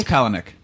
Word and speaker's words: Palahniuk: It's Palahniuk. – Palahniuk? – It does not rhyme with Kalahniuk. It's Palahniuk.